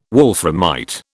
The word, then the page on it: wolframite: British English